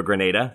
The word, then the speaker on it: Grenada: We talked a little bit before we started recording, and you were watching this game last night, the USA's resounding win over Grenada. That's how I'm supposed to pronounce it, apparently.